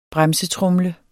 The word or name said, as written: bremsetromle